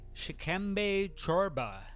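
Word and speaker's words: Shikembe Chorba (шкембе чорба): This week's hangover cure is Shikembe Chorba. – Wow, pretty good on the pronunciation there. Not so bad.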